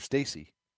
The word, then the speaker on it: Stassi: So they're pretty well set, it would seem, with uh, Chirinos and Max Stassi or Stacy. Not sure why I said that wrong initially, but uh, Chirinos and Stacy would seem to be the catching tandem in Houston.